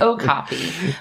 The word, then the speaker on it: okapi: Which I also had to look up how this is pronounced because my entire life I've been calling them Okapi. – Likewise. – I've been saying it wrong, and so have you. – Well, it's Okapi.